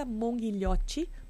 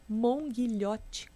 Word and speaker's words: Monghilhotti: É, nas redes sociais é Mônica Monghilhotti. Mônica escreve com K. Monghilhotti, como se fala, com dois T's mudos no final.